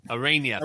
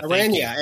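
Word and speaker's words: Araña: I don't know if I pronounced. Aranya. – Aranya. – Aranya.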